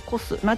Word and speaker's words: KOS: 出店店舗の情報ですが、肉と米、もいもいもい、コス、 KOS と書いてコス、ナチュラルベジ、ベイクショップエブリデイ、カフェバス木漏れ日、米粉キッチン、マヒナ、集まるそうです。